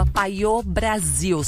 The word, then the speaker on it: Payot: É arroba paiô Brasil. Só que, ó, é PAYOT e Brasil. Paiô. É paiote Brasil. – Escreve paiote com Y, né? Mas a pronúncia é paiô Brasil.